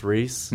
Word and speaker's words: Rhys: I wait, met is a there guy a, with a Y that is pronounced Reese. – Oh, yeah, like, yeah. R-H-Y-S.